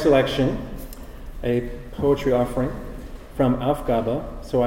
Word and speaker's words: Aufgabe: The emphasis is on the first syllable, Aufgabe, and the last syllable is kind of like a schwa.